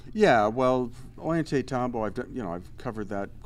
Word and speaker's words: Ollantaytambo: Is it Ollantumbo or whatever? Is that Ollantaytambo? – Much better. Much better than I said.